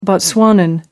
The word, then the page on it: botswanan: American English